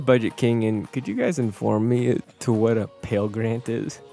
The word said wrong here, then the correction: Pell: Am I saying that correctly? – No. Pell. P-E-L-L.